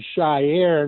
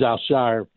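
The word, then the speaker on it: Al-Shaair: So many people are calling him Shire. And I put a thing out on Twitter and say it's one syllable. It's not Shire.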